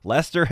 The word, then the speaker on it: Leicester: Seriously, Lester Hemingway. L-E-I-C-E-S-T-E-R. Pretty sure that'd be pronounced Lester, like the city in England or the county.